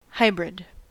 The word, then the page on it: hybrid: American English